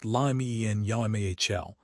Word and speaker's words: Lamine Yamal: The advice for managers is to lean heavily into Spanish assets like Lamine Yamal, pronounced Lamien Yamal, and Dani Olmo, along with defenders like Pedro Porro and Mark Cucurella, pronounced Cucurella.